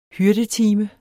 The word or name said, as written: hyrdetime